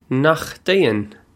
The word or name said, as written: Nach dtéann